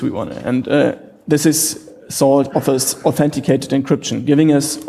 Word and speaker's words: NaCl: It has been developed by Daniel Bernstein, and he called it SALT, but it's spelled N-A-C-L, so I'm sorry for the play on words, but if you see N-A-C-L, it's SALT.